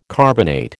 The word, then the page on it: carbonate: American English